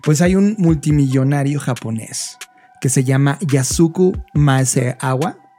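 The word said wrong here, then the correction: Maezawa: o lo estoy pronunciando Maesawa.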